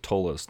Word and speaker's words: Ptolus: Sometimes I hear them pronounce it Tolus, and they're like, "Man, that book, that book."